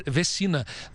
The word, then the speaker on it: Vecina: Eu fiz questão de conversar com o professor Gonzalo Vecina, Vecina na verdade, Gonzalo Vecina, pronunciar o nome dele, senão ele fica chateado, corretamente, senão ele fica chateado.